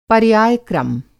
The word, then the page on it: पर्यायक्रम: Hindi